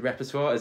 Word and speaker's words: Repertoire: Is it Repertoire? Is that how you pronounce it? – Yeah.